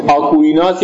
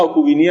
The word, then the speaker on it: آکویناس: قرن چهارم و پنجم به نام آگوستین و برای قرن سیزدهم به نام جناب آقای توماس آکویناس یا آکوینی هم تلفظ می شود.